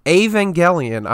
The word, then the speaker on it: Evangelion: When the Netflix garbage version of Evangelion, I'm saying it right.